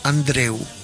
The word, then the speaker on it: Andreu: Andreu es el en catalán, para Andrés. Y se escribe con U, Andreu, tal cual como suena.